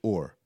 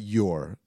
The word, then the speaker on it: Ur: You pronounce it Ur. Not your.